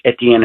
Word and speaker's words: Etienne: He changed the pronunciation of his name. I'm not mispronouncing it. Not Travis Etienne anymore. It's Travis Etienne.